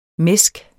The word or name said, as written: mæsk